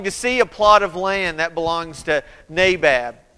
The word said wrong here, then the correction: Naboth: Nabab. I just mispronounced that name, and I realized that, so I want to go back and fix this one. Naboth. Naboth sees his vineyard and says, "I want that vineyard," and says, "I want to buy that."